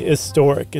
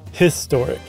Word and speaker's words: historic: No, but it depends on how much you emphasize the H in historic, because most people don't say historic, it's historic. – Right. – Whereas honor, it's like it starts with an O, like the H is silent almost.